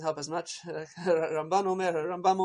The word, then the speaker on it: Rambam: So if it's the Rambam, emphasis on the first half, as opposed to the Ramban, emphasis on the second half.